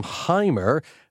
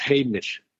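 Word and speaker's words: Heimir: Most Irish people are calling him Heimer, but that's not the pronunciation. Would you just guide us through phonetically how you pronounce the man's name? – Yeah, the pronunciation, the correct pronunciation is Hamish. – Okay, like Scottish. – EI in Icelandic is pronounced A.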